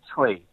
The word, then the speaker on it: Tsui: Well, my last name is Choi, T-S-U-I, pronounced as Choi.